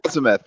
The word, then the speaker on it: Azimuth: He is also a coach and a word I cannot pronounce. Azimuth? Is that true? Right? Azimuth. That's the name of my coaching practice.